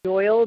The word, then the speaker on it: Doyle: Doyle, D O Y L E.